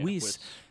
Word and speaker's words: Whis: So now, oh, and also, we cannot forget that Beerus and Whis, am I pronouncing it right? – Yeah, Whis.